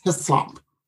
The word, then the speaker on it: Hislop: H as in Harry, I, S as in Sam, L-O-P as in Peter. It's Hislop.